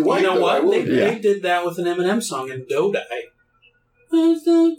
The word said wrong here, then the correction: Dido: Dodai? Is that her name? – Dido. – I have the syllables backwards.